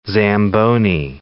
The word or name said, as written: Zamboni